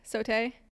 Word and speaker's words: sauté: And then one dancer is doing like a sauté arabesque in the air. Did I say that right? – Yeah, sauté.